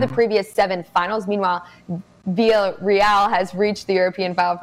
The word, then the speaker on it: Villarreal: Villarreal, absolutely A-plus stuff.